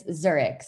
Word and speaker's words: Zurich: Is it Zurich, or how do you say that? – It's Zurich, Switzerland.